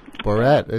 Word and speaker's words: Borat: Well, I know his not real name, but his real name, all I know is the Barat part. – Borat is the latest interpretation of how to pronounce that. I've heard Borat.